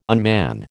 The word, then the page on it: unman: American English